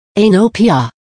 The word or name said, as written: Aenopia